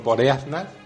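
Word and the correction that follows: Boreanaz: Ah, no sé si lo he dicho bien del todo. Boreanas.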